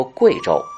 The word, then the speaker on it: 衢州: 自宋代开始称为衢州，当地方言念作贵州。